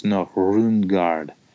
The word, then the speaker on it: Nuremberg: And so Nuremberg, it's hard to do, but it's Nuremberg with a very soft D and almost a roll of the R.